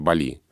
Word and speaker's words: Бали: Но Михаил Абрамович, да, у него ведь позиция, что критерий, как сложилось в русском языке, он очень важный, а для русского уха более привычен вариант Бали. Поэтому он такой вариант и дает. Но вот словарь Легенко настаивает на ударении Бали. Поэтому, да, наверное, здесь выбор просто за редакцией СМИ, на какой словарь ориентироваться. Если СМИ ориентируются во всех остальных вопросах на словарь Легенко, то, наверное, выбирает Бали. Если на словарь Штудинера, то вариант Бали.